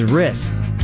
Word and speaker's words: wrist: Your final keyword of the day is wrist, W-R-I-S-T, wrist, as in your wrist will be sore from fist pumping at the concert. Wrist, W-R-I-S-T, the W is silent.